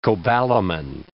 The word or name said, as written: cobalamin